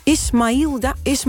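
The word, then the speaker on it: Ismailia: Zeg ik dat goed trouwens, Vincent? – Zeg nog eens. – Ismailda. Ismailda. – Ja, volgens mij zeg je dat wel goed.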